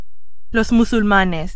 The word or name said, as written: musulmanes